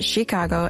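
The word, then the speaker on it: Tchiikago: Am I saying it right? – Yes. Okay. I think people say it differently. Like Chicago. It's fine. Both ways work.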